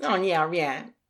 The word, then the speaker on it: Longyearbyen: So today I'm going to talk to you about a little town called Longyearbyen, I think is how it's pronounced. – Longyearbyen.